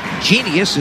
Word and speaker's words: Jeanius: Just for information on the radio audience, Genius is spelled J-E-A-N-I-U-S jackets.